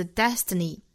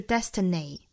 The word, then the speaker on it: destiny: 最后一个单词 destiny，这个词我们要注意它的尾部这个 ni 的发音 e，长元音 e 的短板，大家不要发成了短元音 a，并不是 destiny，而是 destiny， destiny，单词以外结尾的，一般都是要发成长元音 e 的短板 e， e，也就是嘴角还是要裂开的。